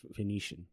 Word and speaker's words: Phoenician: I might mispronounce this name, the Phoenician scheme. – Phoenician. – The Phoenician scheme. There you go.